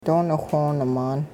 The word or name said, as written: doonixwooni-maan